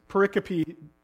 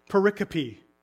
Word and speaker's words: pericope: Another fascinating thing about this pericope, that's seminary terms for story, so section of scripture. So if you want to impress your friends, you can say pericope.